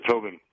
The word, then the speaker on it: Tobin: This is Tobin. – Tobin. – How do you spell that? – T-O-B-I-N.